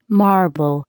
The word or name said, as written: marble